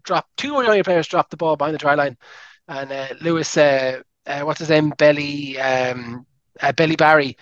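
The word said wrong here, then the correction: Bielle-Biarrey: Apologies, I study French. Even I know I'm pronouncing that wrong. – Belly Barry is the least French name of all time. Yeah. It's Biel Biarri, maybe. – Biel Biarri. Yes. Thank you very much.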